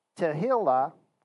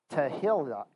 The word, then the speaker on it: Tehillah: So anyways, Tehillah is the way it's pronounced. Oh. It's kind of spelled Tehillah, but it's Tehillah.